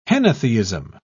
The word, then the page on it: henotheism: British English